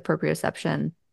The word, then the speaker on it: proprioception: But exactly like you just said with the proprioception, right?